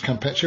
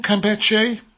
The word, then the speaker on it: Campeche: I think it's pronounced Campeche. We'll find out now as we join John Bonfiglio. Is that right, John? Campeche? – That is absolutely 100% correct.